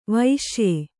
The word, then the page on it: ವೈಷಯ: vaiṣaya